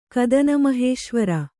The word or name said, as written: ಕದನಮಹೇಶ್ವರ